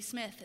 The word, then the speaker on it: Smith: I say, yes, Amy Smith.